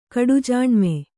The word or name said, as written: ಕಡುಜಾಣ್ಮೆ